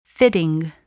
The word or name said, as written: fitting